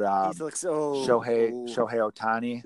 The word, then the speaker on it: Shohei Ohtani: I mean, obviously, they're stuck with Pujols, but Shohei Ohtani.